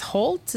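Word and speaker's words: Hoult: Nicholas is Holt. Is that how we pronounce his name? It's H O U L T.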